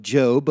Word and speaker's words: Job: J-O-B is pronounced Job.